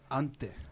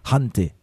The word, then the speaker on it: Hante: Est-ce qu'il faut aspirer comme s'il y avait un H, genre hanté ou. Ah non, pas du tout.